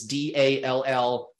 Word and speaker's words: DALL-E: How might the interface of ChatGPT and DALL-E, we didn't even talk about that, that is D-A-L-L-E. Yes, E. So, but it's pronounced DALL-E, by the way.